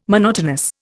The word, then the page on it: monotonous: Indian